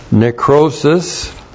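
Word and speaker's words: necrosis (νέκρωσις): Adjunctive chi translated also or likewise, and then necrosis. That's N-E-K-R long O-S-I-S. Necrosis.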